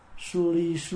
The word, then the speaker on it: suri: Chữ tu rị là mình phát âm suri, tiếng tiếng Phạn là suri ma ha suri.